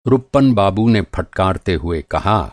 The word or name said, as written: फटकारना